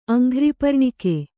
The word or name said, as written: ಅಂಘ್ರಿಪರ್ಣಿಕೆ